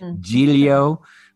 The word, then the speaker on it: Giglio: I'm right now experimenting in Italian cooking, which I should have learned a long time ago, with the last name like Giglio, which is more of the Italian pronunciation.